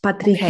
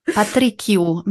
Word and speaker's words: Patricio: Patricio mit dem Weichenden. – Patricio. – Patricio. Betonung auf dem I.